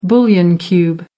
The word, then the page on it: bouillon cube: American English